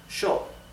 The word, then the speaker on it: yo: Therefore, the pronoun I, yo, is pronounced sho in Argentina and in Uruguay.